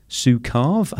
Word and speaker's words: Zukav: And you have chosen, I've got a copy of it in my hand now, Seat of the Soul by Gary Zukav. Have I pronounced this Zukav. – Name? Zukav, sorry, Gary.